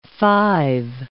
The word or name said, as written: five